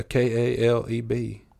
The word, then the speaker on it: Kaleb: I said, yeah, Kaleb. I said, and how do you say it? She said, Aleb. I said, ma'am, do you think it's like knife? The K silent?